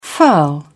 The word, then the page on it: furl: British English